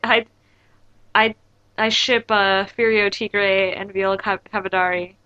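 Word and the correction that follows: Cadaverini: I can't say her name. – Cadverini, thank you.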